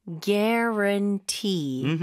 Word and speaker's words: guarantee: 이거 스펠링이랑 발음이랑 주의하셔야 될 것 같아요. Guaran 이렇게 될 수는 없죠. 스펠링이 G-U-A로 시작을 하지만 U가 거의 발음이 없다고 봐야 될 것 같아요. – 맞아요. 그냥 뭐 G-E-R로 생각해도 좋을 것 같아요. Gare. Guarantee.